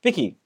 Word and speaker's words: Viki: And then, so obviously, then you moved into uh, is it Viki? Am I pronouncing it right? – Yes. – Viki.